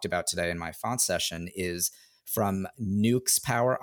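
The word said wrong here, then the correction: Neuxpower: I know I'm mispronouncing New. – That. – New Power. – New Power. Okay.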